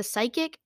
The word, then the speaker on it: Psych: It's not called Psyche? – No, it's called Psych. – Psych. – Except it's spelled like Psyche. It's spelled Psyche, right? – P-S-Y-C-H.